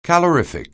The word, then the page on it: calorific: anglais